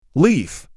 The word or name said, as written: Leaf